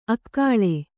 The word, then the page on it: ಅಕ್ಕಳೆ: akkaḷe